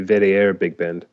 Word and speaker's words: WDR Big Band: And correct me if I said that wrong. – No, that's correct. I mean, that's how they would say it in English. In German, they say WDR Big Band, which is stands for Westdeutscher Rundfunk, which means West German Radio.